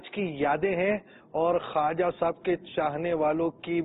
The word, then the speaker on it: ख्वाजा: वैसे लिखने में तो ख्वाजा लिखा जाएगा, लेकिन पढ़ने में बाव साइलेंट करेंगे, ख्वाजा पढ़ेंगे आप, तो पढ़ने के और हैं अल्फाज और लिखने के अल्फाज और हैं, ये वाव साइलेंट है तो ये पढ़ा नहीं जाएगा। तो ख्वाजा गरीब नवाज रहमतुल्लाहि ताला अलैह,